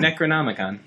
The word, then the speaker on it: Necronomicon: How do you say that word? – Necronomicon. – Did I get it the second time? – I think you did.